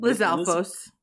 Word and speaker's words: Lizalfos: Lizvalos? How do you say that name? – Lizalfos. – Lizalfos? I said it right.